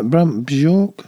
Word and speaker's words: Brant Bjork: Brant Bjork. Is that right? Brant Bjork? – Yeah.